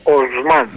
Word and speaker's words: Οσμάν: Είναι Οσμάν. Δεν υπάρχει. Τα τουρκικά είναι τα ονόματα, τονίζονται στη λίγουσα.